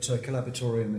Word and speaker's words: Collaboratorium: And I'm sure I've said that wrong because it's the Collaboratoria, isn't it? Have I got that right? – It varies, but Collaboratorium is fine, yes.